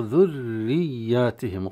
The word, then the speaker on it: ذرياتهم: فنطقها في هذه الحال في الصعود، ثم بعدها ياء مشددة والياء يتطلب جريان الصوت، فتنطق ذرياتهم، وقليل من ينطقها هكذا البريق ذرياتهم ذرياتهم شدتين راء مشددة وياء مشددة، وأحيانا يعني يفخم الراء أيضا.